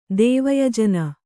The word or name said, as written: ದೇವಯಜನ